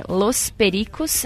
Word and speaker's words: Los Pericos: Aqui, corrigindo a pronúncia, é Los Pericos.